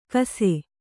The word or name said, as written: ಕಸೆ